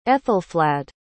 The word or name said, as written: aethelflaed